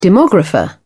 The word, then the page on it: demographer: British English